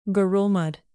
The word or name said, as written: ngerulmud